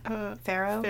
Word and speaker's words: farro: There's lentils, farro, did I say that right? Farro? – Farro? Yeah, farro.